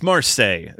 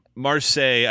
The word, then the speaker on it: Marseille: It's Marseille is the name of the song. It's the French song, Marseille. I read it phonetically, and I don't want to seem like an uncultured swine. So I know it in Spanish. I just know it's La Marseillaise. That's all I can tell you. – Okay. Marseille, I believe, is the correct French pronunciation.